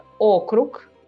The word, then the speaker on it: okrug: Uh, outra palavra, okrug, também, como, como a Galha já falou, com sílaba tônica uh, O okrug, e as cidades ah, federais a gente chama de Город федерального значения.